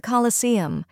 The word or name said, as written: Colosseum